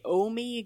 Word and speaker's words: Ohme: So my wish you were here is the Omi Gardens in Wenatchee, Washington. So that is spelled O-H-M-E.